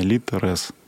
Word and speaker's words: ЛитРес: Поэтому мы приветствуем двойное ударение ЛИД, РЭС. Но, в принципе, неважно, как ставят ударение, главное, чтобы пользовались литературный ресурс.